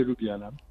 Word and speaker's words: Ljubljana: Ayúdame a decirlo, por favor. Lo voy a decir mal. Ljubljana, ¿cómo se dice? – Es la Ópera y Ballet Nacional de Ljubljana.